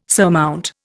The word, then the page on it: surmount: Indian